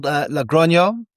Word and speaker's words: Logroño: No, the area is called Logroño in La Rioja.